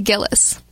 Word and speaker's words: Gilles: Or it's actually Gillis.